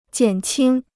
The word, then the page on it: 减轻: jiǎn qīng